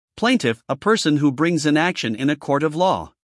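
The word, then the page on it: plaintiff: uk /ˈpleɪntɪf/; en-us /ˈpleɪntɪf/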